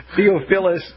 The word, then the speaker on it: Theophilus: This was according to Theosyphilus. Did I say that right? No, that sounds like a venereal disease. I didn't mean to say it that way. Theophilus Parsons, or Theophilus Parsons.